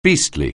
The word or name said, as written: beastly